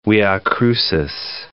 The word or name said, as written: via crucis